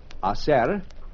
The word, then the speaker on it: hacer: First, the five verbos, the five verbs to make or do, hacer, hace mucho tiempo, to be able, poder, P-O-D-E-R, hacer is H-A-C-E-R, but the H is not pronounced, to arrive, llegar, L-L-E-G-A-R, to say or tell, decir.